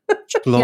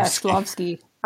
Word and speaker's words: Shklovsky: Shklovsky. – Yeah, Shklovsky.